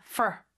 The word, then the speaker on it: for: 그다음에 for 같은 경우는 진짜 짧게 발음이 돼요. 그래서 거의 그냥 으 발음도 없고 그냥 fr 발음만 낸다. for 이렇게 낸다 생각하시면 되고요. teaching, t 길게 끌어야 되겠죠?